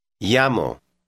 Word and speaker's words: llamo: The double L is pronounced ya. Listen and repeat word 5. – Llamo.